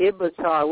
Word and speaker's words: Ibatar: It's Ibatar with a B.